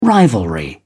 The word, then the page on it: rivalry: British English